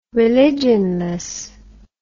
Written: religionless

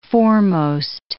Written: foremost

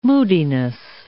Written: moodiness

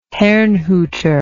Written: herrnhuter

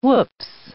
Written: whoops

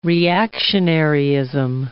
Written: reactionaryism

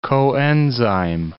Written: coenzyme

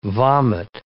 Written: vomit

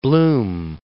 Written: Blum

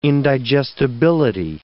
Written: indigestibility

